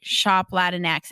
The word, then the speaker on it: Shop Latinx: So to start, what inspired the transition to go to Shop Latinx? Am I saying it right, by the way? – Yeah. Yeah, you got it.